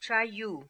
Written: Chai Yoo

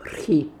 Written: Rkhee

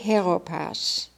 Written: Heropass

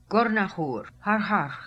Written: Gornahoor Harharkh

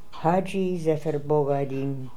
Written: Zephir Bogga Eddin Hadji

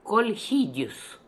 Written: Kolhidious

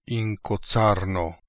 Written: Inkozarno

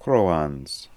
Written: kroahns

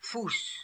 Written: Foos

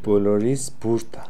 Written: Polorishboorda

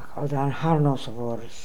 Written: Akhaldanharnosovors

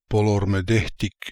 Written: Polormedekhtic

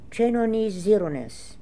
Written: Chainonizironness

